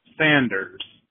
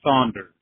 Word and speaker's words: Sanders: Could you please pronounce the name Sanders instead of Saunders? I've been listening to you for years now, and you've been pronouncing Bernie Saunders and Colonel Saunders from KFC, and it really irritates me.